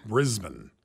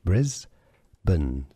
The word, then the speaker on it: Brisbane: Briz, so you could spell it B-R-E-Z. Brisbane. So that's a schwa sound. – Brisbane. – Stress on the first syllable.